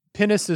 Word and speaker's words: pinnace: Also, Pinnace is spelled P-I-N-N-A-C-E.